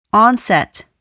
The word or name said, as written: onset